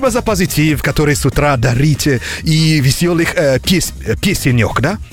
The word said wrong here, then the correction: песенок: Песенок. – А, песенок, окей. Я думаю, песенек. – Песенок. Ты поаккуратнее повторял бы это слово. Там чуть-чуть ударение не туда, и все.